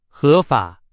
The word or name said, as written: 合法